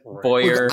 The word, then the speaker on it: Bowyer: I think it's Bowyer, but Bowyer. – I like your pronunciation better.